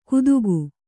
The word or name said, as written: ಕುತುಕು